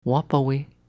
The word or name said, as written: Wappawey